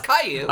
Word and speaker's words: Caillou: That one, that's Caillou. – I googled K-I-W-Y-O-U, and then...